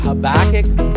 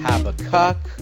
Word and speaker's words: Habakkuk: Habakkuk, Habakkuk, take your pick on pronunciations. Habakkuk is probably right.